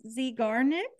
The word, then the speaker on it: Zeigarnik: I'm probably going to butcher this word, the Zeigarnik effect. Yes, yes, great, perfect. Okay, cool. – And WOOP goals. So can can you explain those to my listeners? – Sure. The Zeigarnik effect is r- very interesting.